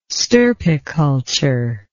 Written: stirpiculture

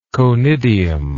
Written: conidian

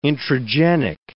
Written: intragenic

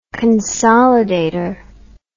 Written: consolidator

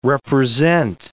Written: represent

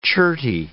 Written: cherty

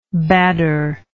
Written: badr

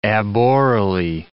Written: aborally

